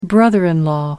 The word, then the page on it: brother-in-law: American English